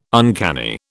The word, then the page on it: uncanny: British English